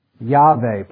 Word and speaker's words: Yahweh: Yahweh probably is the pronunciation, but we don't know that for certain.